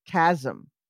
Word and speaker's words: chasm: This says I'm looking up it says cat chasm with a K in the pronunciation section.